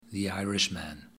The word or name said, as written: the irishman